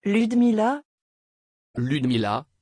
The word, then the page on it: Ludmila: French